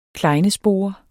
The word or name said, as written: klejnespore